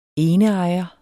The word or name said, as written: eneejer